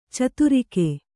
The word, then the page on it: ಛತ್ರಕ: Kannada